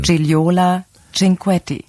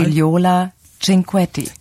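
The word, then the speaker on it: Gigliola Cinquetti: Giliola Cinquetti. – Das war die ARD-Aussprache-Datenbank, klingt gut, ne? Nochmal. – Giliola Cinquetti.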